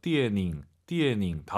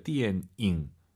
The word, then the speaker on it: 电影: 디에닝 다 알아들어요. 안 됩니다. 근데 니은 들리면 안 돼요. 디엔잉입니다. 잉 여러분 꼭 기억하시고요. 디엔잉 연음 현상 조심하시기 바랍니다.